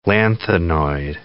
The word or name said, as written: lanthanoid